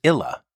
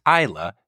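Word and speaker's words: Ila: The panda in question has her name spelled I-L-A. I always saw it and thought it was Illa. It turns out the keepers at Woodland Park Zoo, where she was born, had pronounced her name Illa. At Toronto, they interpret it as Isla, so now that is her name. Three letters, three possible pronunciations.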